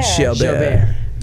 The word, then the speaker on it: Jalbert: Just think of Albert. – Yeah, Albert with a J. – Or it's French. French, so it's Jalbert. – Jalbert. – Jalbert.